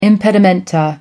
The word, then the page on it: impedimenta: American English